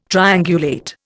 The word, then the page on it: triangulate: Indian